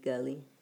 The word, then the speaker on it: Gulley: And there's my Facebook is my first and last name, Dominique Gully, and G-U-L-L-E-Y.